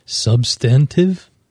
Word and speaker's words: substantive: Days on market, this is a kind of sub- substantive. That's a tough word. – There's a, I think you got an extra syllable in there. Substantive? – Meaningful.